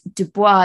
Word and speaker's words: Du Bois: And interestingly, I've known it to be pronounced Du Bois, but the audiobook said Du Bois. So we're going with that. – Yeah, I actually looked into why. And Du Bois is obviously the French pronunciation, but W.E.B. Du Bois himself was a Black African-American man. So his name was anglicized. So that's how history has remembered him. Hence, Du Bois.